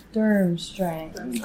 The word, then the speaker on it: Durmstrang: Durmstrang. – Durmstrang. Yeah, yeah. I was like, that's how you pronounce it, right? – Yeah. – Okay, we're good? – Yeah, you're good.